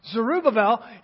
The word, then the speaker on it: Zerubbabel: Joshua is the head of the priests and Zerubbabel. Roy taught me how to pronounce that, by the way. Zerubbabel, he was the governor.